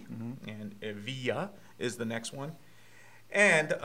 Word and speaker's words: Evija: It's pronounced it's spelled E-V-I-J-A, but according to Lotus, it's pronounced Evija.